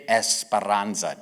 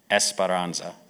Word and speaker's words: Esperanza: He is a pastor and lead elder of the church in Costa Rica, San Jose, Iglesia del Pacto Nueva Esperanza. Did I say that right? Nueva Esperanza. You don't roll the R's. Esperanza. Esperanza? No. See, I told you.